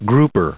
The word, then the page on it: grouper: grou*per; American English